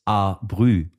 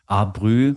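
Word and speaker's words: art brut: Und das wiederum, dieses Buch, führte nochmal zwei Jahrzehnte später zur Entwicklung des Begriffs A-Brü. Ah, nein! Ja, also auch zu dem Begriff, der die Band von Eddie ausmacht, die da Art Brute ausgesprochen werden soll.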